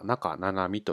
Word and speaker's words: 中七海: なお、えー、読み方は中七海と読みます。